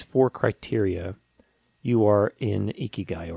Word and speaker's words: ikigai: You know, the Japanese have this thing called ikigai.